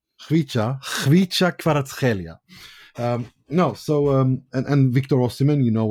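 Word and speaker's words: Khvicha Kvaratskhelia: No, it's Hlicha Kvaratskhelia. He said it himself. They because there were so many questions, he did a video. Napoli put out a video with him pronouncing his name, and I listened.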